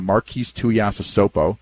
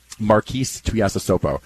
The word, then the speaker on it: Marquise Tuiasosopo: He's had a good thing going, and I remember talking to Marquise Tuiasosopo. Remember that name? – He was well a second round draft. Well pronounced. – Marquise Tuiasosopo.